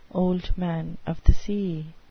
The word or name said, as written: old man of the sea